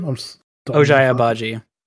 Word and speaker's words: Ochai Agbaji: Ochai Agbaji.